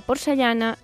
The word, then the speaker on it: porcellana: Fixeu-vos que la paraula porcellana s'ha d'escriure i s'ha de pronunciar amb doble L.